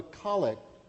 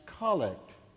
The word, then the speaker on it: collect: You notice the name of this kind of prayer is pronounced collect, with the accent on the first syllable, not as normal in English, with the accent on the second syllable, collect.